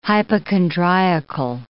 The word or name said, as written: hypochondriacal